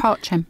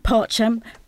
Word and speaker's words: Pacem: This is, let me pronounce it properly, Dona Nobis Pacem. – Parchem. – Parchem by Bach from the Mass in B minor.